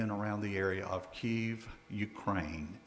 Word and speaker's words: Kyiv: And yes, if you're like me, Kyiv is the same city that I was taught to pronounce, Kiev. It is the same, and I believe now the spelling is K-Y-I-V.